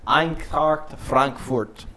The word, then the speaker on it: Eintracht Frankfurt: Okay, last game is Eintracht Frankfurt against Lazio. – Eintracht Frankfurt. – Mm. How's that pronunciation? – That's perfect.